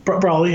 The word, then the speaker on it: Broly: Broly, yeah.